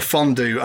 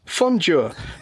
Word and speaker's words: fondue: Here, you have fondue that's supposed to rhyme with stew and crew, and it does that job perfectly fine. Why do you deliberately mispronounce it fondue so that it doesn't rhyme with either of those two words?